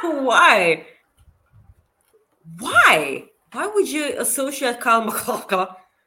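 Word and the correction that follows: MacLachlan: Kyle McLaughlin.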